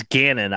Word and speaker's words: Gannon: Maybe Houston, and it's not O'Bannon, sorry, it's Gannon. I mispronounced his last name.